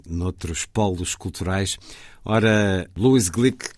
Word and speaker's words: Glück: Agora, Louise Glick, vamos adotar este, esta pronúncia porque não é.